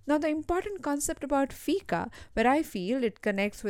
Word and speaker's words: fika: While dealing with an emotional or mental breakdown or burnout, you can think about the Swedish noun which is called fika.